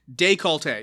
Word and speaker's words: décolleté: Décolleté. You got it correct. D-E-C-O-L-L-E-T-E, and there's two accents on the first. There's an accent on the first and last E.